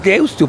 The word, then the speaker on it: Deusto: Es Deusto, porque se escribe con U final.